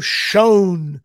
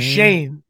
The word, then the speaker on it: Schoen: Giants hire a guy that looks like it's pronounced Joe Schoen, but it's actually Shane. And I love that last name. That was my dad's favorite movie. Shane, come back. So it is Shane.